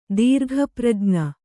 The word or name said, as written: ದೀರ್ಘಪ್ರಜ್ಞ